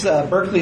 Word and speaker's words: Berkeley: So it's pronounced, the first syllable is like bark like a dog. So remember, it's Berkeley, not Berkeley.